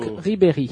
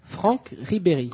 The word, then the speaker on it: Franck Ribéry: Franck Ribéry. – Tá ok, obrigado. – Como que é? – Franck Ribéry.